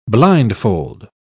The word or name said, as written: blindfold